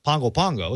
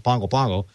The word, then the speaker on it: Pago Pago: The capital city there is pronounced Pongo Pongo. It's spelled Pago Pago. It's pronounced Pongo Pongo.